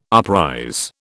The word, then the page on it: uprise: British English